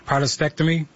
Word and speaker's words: prostatectomy: I see that you have a specialty in robotic-assisted prostatectomy.